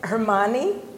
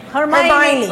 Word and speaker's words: Hermione: She wanted her to make Hermione, did I say the name correctly? – Mm-hmm. Rowling. – No, Hermione. – Hermione.